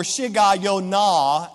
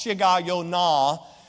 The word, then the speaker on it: Shigionoth: In verse number 1, the Bible says a prayer of Habakkuk the prophet upon Shigionoth, or Shigayonah, as it's pronounced, Shigayonah.